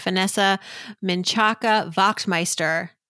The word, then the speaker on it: Vanessa Menchaca-Wachtmeister: Thank you so, so much, Vanessa Menchaca-Wachtmeister.